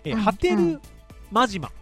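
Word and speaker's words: 波照間島: ハテルマジマかな、うん、すみません、読み方間違えてました。最西端がハテルマジマですね。 – ハテルマジマ。 – 最東端が南鳥島ですね。 – 難しい。ハテルマジマ。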